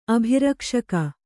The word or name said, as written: ಅಭಿರಕ್ಷಕ